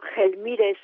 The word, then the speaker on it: Gelmírez: Diego Gelmírez, je vais le prononcer à l'espagnol, est un archevêque de la cathédrale de Saint-Jacques de Compostelle.